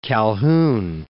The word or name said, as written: Calhoun